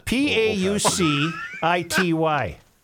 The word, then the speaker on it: paucity: P A U C I T Y.